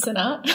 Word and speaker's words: Sinnott: How do you like to pronounce your last name? – Well, I like to pronounce it Green most of the time, but my married name's Sinnott.